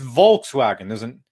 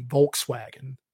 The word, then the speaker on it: Volkswagen: But it's Volkswagen. There's a fucking L in it.